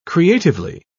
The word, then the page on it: creatively: British English